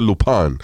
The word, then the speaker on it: Lupin: se escribe Lupin, pero él se pronuncia Lupin.